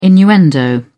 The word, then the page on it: innuendo: British English